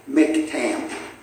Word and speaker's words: Miktam: It's Mik, okay? Miktam. You say, where'd you find that at? Oh, a website that has one of those click on the button, you know, to hear what its pronunciation is. And so that's how I found that out.